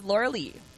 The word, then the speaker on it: Lauralee: My name is Laura Lee. – No, it isn't. – Get out. How do you spell it? – What's your last name? – Armstrong. – I'm Laura Lee Rose. It's Laura, L-A-U-R-A-L-E-E. – See, I'm the weird one. I'm L-E-A-H.